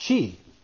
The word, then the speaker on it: Xi: But there's also another guy. His name is spelled XI. It's pronounced Chi. His name is Xi Jinping.